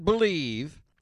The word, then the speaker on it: Bleav: Use the promo code BELIEVE. It is the Believe Radio Network that powers the JT and Looney podcast, but it's spelled B-L-E-A-V. It's missing a vowel. B-L-E-A-V, and that's the code you have to use to get your 50% welcome bonus.